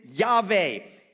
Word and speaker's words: Yahweh: His proper name is spelled Yod-Heh-Vah-Heh. We pronounce it Yahweh.